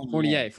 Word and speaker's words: Fournier: Evan Fournier. I can't say his name. Is that correct? – Fournier, sorry.